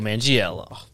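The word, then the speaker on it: Manganiello: Played by Mr. Joe Mangiello. – Exquisite. – That's how you pronounce his last name.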